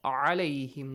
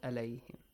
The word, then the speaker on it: عليهم: So, alayhim, not alayhim. Alayhim has another meaning. Alayhim, another meaning. So, two different meanings. So, you have to say alayhim.